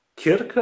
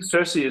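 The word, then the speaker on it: Circe: I think Cersei is the official English pronunciation of that Greek goddess, so maybe use that.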